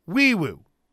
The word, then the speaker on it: Nwaiwu: Spelled N-W-A-I-W-U, pronounced Wiwu. 6'4", 316-pound offensive lineman out of Coppell, Texas.